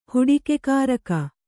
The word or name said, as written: ಹುಡಿಕೆಕಾರಕ